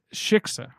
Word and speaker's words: shiksa: And then the shishka comes in. – Shiksa. It's Shiksa. Incredible. – I keep saying it wrong.